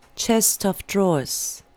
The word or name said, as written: chest of drawers